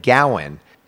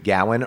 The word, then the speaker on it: Gawain: And yes, I am talking about that famous dude, Gawain or Gawain, whichever you want. One's Saxon pronunciation. One is more French because they put the emphasis at the end.